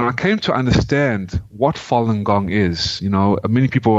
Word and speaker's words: Falun Gong: And it's pronounced Falun Gong.